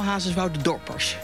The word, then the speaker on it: Hazerswoudendorpers: Hazerswoudenaren. Zeg ik dat goed? – Ja, zeker. Allemaal Hazerswoudendorpers.